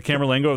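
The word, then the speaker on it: camerlengo: Camerlingo, am I saying that right? – Yes, the Camerlingo of the Holy Roman Church, the office of the papal household that administers property and revenues of the Holy See.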